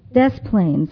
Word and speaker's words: Des Plaines: from DePlain, Illinois. Did I say it right? DePlains? Des Plaines.